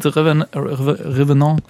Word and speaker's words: The Revenant: The Revenant wird französisch auch ausgesprochen, weil da geht es auch ein bisschen um französische.